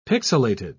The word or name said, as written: pixilated